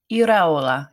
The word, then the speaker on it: Iraola: How do you say, is it Andoni Iraola? – Iraola. Yeah. – Iraola. Okay.